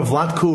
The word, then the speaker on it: Vladko: Well, remember, Vladko rhymes with no.